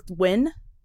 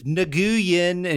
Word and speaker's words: Nguyen: And they're not pronounced at all like an N or a G. They're pronounced, isn't it like win? – Yeah. – Like, but people will be like Nguyen, and you're like, that's like, not win. How it goes at all.